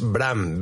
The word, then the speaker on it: Bram: De hecho, Brand Stoker, el escritor, eh, su nombre es Bram, B-R-A-M, como, como el niño de, de Juego de Tronos, pues igual.